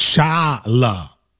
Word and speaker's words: Shyamalan: Well, now I know it's Shyamalan. I know it's like the whole.